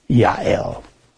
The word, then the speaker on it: Jael: J's are pronounced Y in the Hebrew. It's actually Y-L. Jael.